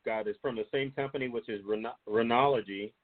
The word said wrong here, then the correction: Renogy: or Renogy, sorry, wrong pronunciation.